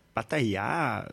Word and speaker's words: Pattaya: Maar ik heb er ook een heleboel gefotografeerd in Pattaya, zo spreek je dat uit.